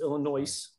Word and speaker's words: Illinois: You all have been pronouncing it incorrectly. It's Illinois. That's a hard S.